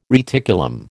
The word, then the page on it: reticulum: American English